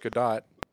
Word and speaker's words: Gadot: Which actually her name, last name is pronounced Gadot.